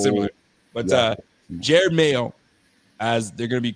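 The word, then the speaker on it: Jerod Mayo: I'm saying Jared Mayo. Am I saying that right? Because it sounds – yep, Jared Mayo. Gerard. Did I say Gerard Mayo?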